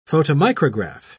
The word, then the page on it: photomicrograph: British English